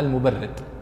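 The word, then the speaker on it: المبرِّد: قال المبرد وليس المبرد يا سعد، المبرد محمد بن يزيد المبرد، هذا من علماء النحو البصريين المشهورين جدا، وينطقون اسمه بالكسر المبرد والمبرد بالفتح، والصحيح أنه المبرد بالكسر،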